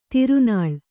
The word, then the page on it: ತಿರುನಾಳ್: kannada